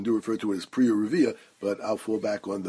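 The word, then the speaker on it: Priya Urivya (פריה ורביה): I should point out that the dikduk proper way to pronounce Piriyah V'Rivya is Priya Urivya. But the vernacular usage, the way I was always taught by my Rebbeim, was Piriyah V'Rivya.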